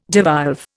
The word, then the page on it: devolve: Indian